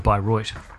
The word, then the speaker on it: Bayreuth: Or Bayreuth. – Is that how you pronounce it? – Yeah. – Good to know.